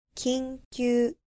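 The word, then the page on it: 緊急: Japanese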